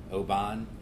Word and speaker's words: Oban: Scottish whiskeys, Glenlivet, Macallan, Oban, is that how you say it? – Mm-hmm.